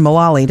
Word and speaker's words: Mullally: Original stars Eric McCormick, Deborah Messing, Sean Hayes, even Megan Mullally.